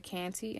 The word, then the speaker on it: Kante: But my name on Facebook is Fanta Canty, and it's spelled F-A-N-T-A, and my last name is K-A-N-T-E.